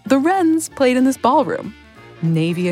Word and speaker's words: Rens: Wrens, by the way, it's not the bird. It's spelled R-E-N.